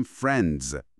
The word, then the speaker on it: friends: در انتهای کلمه جمع عام ما اصلا صدای سوتدار سین نمیشنویم، یعنی دقیقاً باید بگیم فرندز با صدای ز.